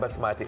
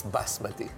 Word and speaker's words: basmati: And it's not basmati, it's basmati.